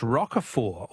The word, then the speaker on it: Roquefort: The French Roquefort, also with a silent T, with a silent T, is very smelly.